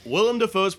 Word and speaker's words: Willem: Willem Dafoe.